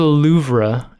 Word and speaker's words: Louvre: Like if you go to the Louvre and you're expecting the Mona Lisa to be like 10 feet tall.